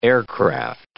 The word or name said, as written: aircraft